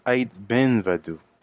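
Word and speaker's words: Aït Benhaddou: It's pronounced it's pronounced Ait Ben Vadu.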